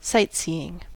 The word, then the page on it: sightseeing: American English